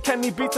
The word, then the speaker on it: Kenny Beats: Duke a collaboration with Quenny Beats. Have I heard of Kenny Beats? Of course he brings the heat. I mispronounced his name, so I had to correct it.